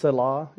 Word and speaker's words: Selah: It's pronounced Selah.